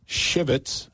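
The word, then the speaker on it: Shivwits: go through Shivitz. I always want to say that on there. Got to say that carefully. – I guess a W is silent on that.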